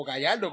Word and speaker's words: Gallardo: Lamborghini Gallardo, o Gallardo, como se pronuncia, LP Gallardo, okay.